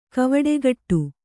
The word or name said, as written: ಕವಡೆಗಟ್ಟು